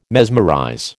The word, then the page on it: mesmerize: American English